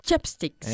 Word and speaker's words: chopsticks: Not chopsticks, sino chopsticks. Ch-ch-ch, como chorizo. – Chopsticks.